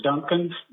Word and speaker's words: Duncan: My name is Arthur Duncan, D-U-N-C-A-N.